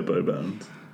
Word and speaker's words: Beauxbatons: How do they pronounce that? – Beauxbatons? – Yeah, Beauxbatons.